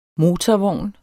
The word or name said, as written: MOTORVOGN